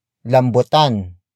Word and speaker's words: lambutan: Lambutan nyo ang pagsasayaw.